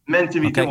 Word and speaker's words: Mentimeter: mind meter, Je, je, prononce peut-être pas bien, mais Mentimeter, c'est ça, Mentimeter, c'est ça.